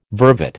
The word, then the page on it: vervet: American English